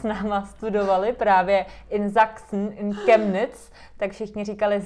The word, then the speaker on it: Sachsen: Tak třeba Sachsen nebo Wachsen, že píše se to vlastně jako ch, ale vyslovuje se to jako k.